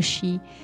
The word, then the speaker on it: sidh: Ça s'écrit S-I-D-H, hein, comme tous les mots de gaélique, ça n'a rien à voir la prononciation avec l'écriture, mais c'est le Shi.